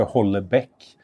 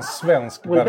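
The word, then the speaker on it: Houellebecq: Men däremot så säger jag inte heller mer som det låter på franska, Welbeck. – Welbeck. – Welbeck. – Säger de så? Eller? – Ja. Så säger Welbeck. Jag inte heller. Utan Welbeck. Jag säger en svensk Welbeck. Version av det korrekta uttalet.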